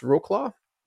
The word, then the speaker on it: Wrocław: and I believe it's pronounced Wrocław in Poland.